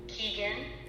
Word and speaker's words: Keegan: Sure, it's Vicky Keegan, K-E-E-G-A-N.